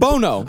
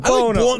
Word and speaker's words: Buono: Bueno's? How do you say his name? – Bono. – Bono. – Like Sonny Bono. – I know, but I see it as Bono. Bueno. – Yeah, it's spelled Bueno. – You just got racist eyeballs. – But and he's Italian. He's not Latin. – All right, he's Bono.